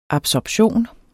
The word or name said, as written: absorption